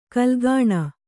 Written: ಕಾಲುಗುಣ

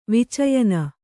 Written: ವಿಚಯನ

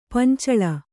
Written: ಪಂಚಾಲ

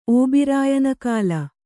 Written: ಓಬಿರಾಯನಕಾಲ

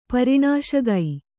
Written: ಪರಿಣಾಶಗೈ

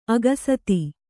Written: ಅಕ್ಷತ

